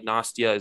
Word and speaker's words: Nastia: Pepsi, Budweiser, like Nastia. Is that how you pronounce her name? – Yeah, that's perfect.